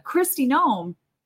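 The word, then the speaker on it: Kristi Noem: You know, with Christy Nome. Am I getting her name right? It's been a while. – Yeah, yes. You got it.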